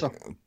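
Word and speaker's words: Tulsa: У него только что было ралли в Тулсе или Талсе, как это произносится по-русски. Я так Толса. – Талс, да, ну мы говорим Талса. – Ну, у ней А, как Москва. Вторая негласная А, Толса.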